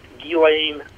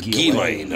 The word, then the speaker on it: Ghislaine: And I still, Barry, how do you say, is it Ghislaine? Is that how you say her name? – Television has, says a million different things, but it's pronounced Ghislaine Maxwell. – So it is Ghislaine, okay.